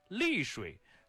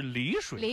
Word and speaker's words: 丽水: 然后那个丽水，它正确读音是丽水，丽水。 – 对，然后呢，它写的就是丽，哎呦，真的好要命哦。